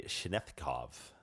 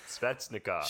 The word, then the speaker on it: Svechnikov: Another exciting rookie from the 2018 class would be Andrei Shenetkov. I believe I said that right. Right wing on the... Shenetkov. Right wing on the 18th. – Svechnikov.